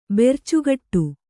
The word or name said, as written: ಬೆರ್ಚುಗಟ್ಟು